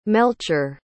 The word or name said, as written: melchor